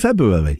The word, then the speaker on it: February: There are two R's in February.